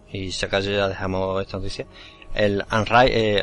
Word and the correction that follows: Underride: eh, Underride, mm, lo he dicho como, como se escribe, es una especie de Fallout Metro 2033 en 2D tiene una pinta acojonante.